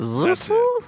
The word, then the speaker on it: Sisu: S-I-S-U? – Yeah, I think Zissa? Is it Zissa? – Sisu. That's how you say it.